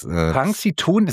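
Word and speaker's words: Punxsutawney: Punxsutawny, so wird das ausgesprochen. Ja, punxsutawny.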